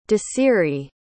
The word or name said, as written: desirie